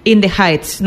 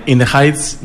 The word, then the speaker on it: In the Heights: Tu primera recomendación es In the Heights. No sé si lo he pronunciado bien. – Perfectamente. In the Heights,